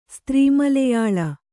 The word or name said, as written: ಸ್ತ್ರೀಮಲೆಯಾಳ